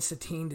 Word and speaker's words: Satin: Is that how they pronounce it? I don't know. – Satine? – I think it's Satin. Apparently. – I was going to say, for 85 years, I thought it was Satin.